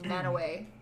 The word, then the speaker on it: Mantua: I used to live near a town called Manaway, and it's Native American, but it's spelled Mantua.